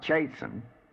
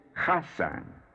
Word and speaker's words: Chasan: Its angel is Chasan, not Chasen. You remember the CH is guttural, as in the CH of the Scotch word loch, meaning a lake. Its pronunciation is Chasan. Chasan.